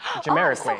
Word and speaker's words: Jamiroquai: How do you pronounce it? – Jamiroquai. – Oh, I was so close.